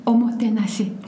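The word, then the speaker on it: Omotenashi (おもてなし): Eh, jag tänkte börja med att fråga, om, nu, nu ska jag våga mig på att säga ett ord där så får du rätta mig på uttalet då, men jag tänkte fråga om Omotonashi. – Omotenashi. – Omotenashi.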